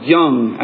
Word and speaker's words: Jung: And so he said he likes to quote Carl Jung, who was, or Carl Jung, I think it's pronounced with a Y.